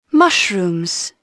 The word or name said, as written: mushroom